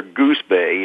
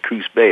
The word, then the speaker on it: Coos Bay: A lot of people, you know, when they pronounce it, they pronounce it either Coos Bay or Goose Bay, you know, sometimes, and it's actually just Coos Bay.